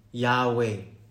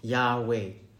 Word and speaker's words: Yahweh: And in this case, we understand it to be pronounced Yahweh, or something very close to that, Yahweh.